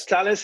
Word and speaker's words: Thales: It's a partnership with, I believe it's Thales. Is that how you pronounce that? Thales Alenia Space? – Yes, Thales Alenia Space, which is a large European space manufacturing company.